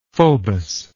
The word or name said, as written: phobos